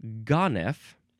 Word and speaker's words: ganef: Now, it looks like you pronounce it Ganef. It is spelled three different ways, G-A-N-E-F, or G-O-N-I-F, or G-O-N-I-F-F, Ganef.